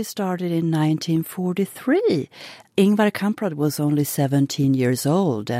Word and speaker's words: Ingvar Kamprad: Ingvar Kamprad.